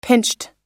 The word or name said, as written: pinched